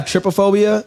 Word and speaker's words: trypophobia: I have trypophobia, but that fear of like a bunch of holes. – Trypophobia, that's what it's called. Yeah, that's how you pronounce it.